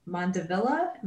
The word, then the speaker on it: Mandevilla: The Mandevilla, am I saying that right? – Mm-hmm.